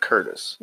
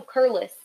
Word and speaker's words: Curless: It's not Curtis. – No, Curlis. C-U-R-L-E-S-S.